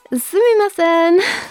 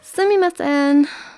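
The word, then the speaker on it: sumimasen (すみません): The next one means excuse me, and it is pronounced sumimasen. I'm trying to pronounce it like Japanese. If I pronounce it in my actual accent, sumimasen, it doesn't really it doesn't really sound right.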